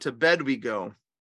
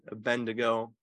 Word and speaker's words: Abednego: The story of Shadrach, Meshach, and to bed we go. – Is that how you pronounce that? I never knew that. – It's a joke with the kids, you know, Shadrach, Meshach, and Abednego.